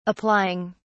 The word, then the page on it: applying: British English